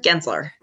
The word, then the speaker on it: Gensler: So now that you're working for a big firm now, which, how do you pronounce the company's name once more? Is it Gensler? – Gensler. Yeah.